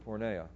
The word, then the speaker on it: porneia: The Greek word porneia. Jer, is that close, do you think? Cool. Awesome. Yes. Porneia. Who can guess what word we have that we get from this word porneia?